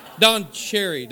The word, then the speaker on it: Don Cherry: Don Cherry.